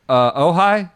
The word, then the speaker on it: Ojai: Ojai, is that right? Is that how you pronounce it? – Yeah, Soul Park and Ojai, first event back.